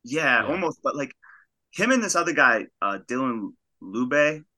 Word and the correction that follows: Lubke: he's from Lubke from North New Hampshire. – You know, yeah, that's how you pronounce his name.